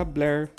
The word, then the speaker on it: Blur: Antes mesmo da fama, que ele teve uma fama em 2003, Banks estampou a capa do sétimo álbum do estúdio da banda Blair, que se escreve B-L-U-R.